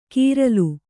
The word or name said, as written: ಕೀರ್ಲು